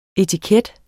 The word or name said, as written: etiket